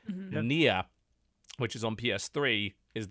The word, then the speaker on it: Nier: Nier. It's spelled N-I-E-R.